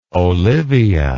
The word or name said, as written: olivia